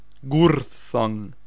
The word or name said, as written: Gurthang